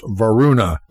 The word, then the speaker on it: Wardruna: Also, Wardruna is pronounced Varuna, but that's an easy mistake to make as an English speaker, so it doesn't really matter.